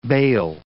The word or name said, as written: bail